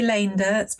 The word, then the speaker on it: Leendertz: Well, I do say Leendertz, but it's fine. A double E in Dutch is A.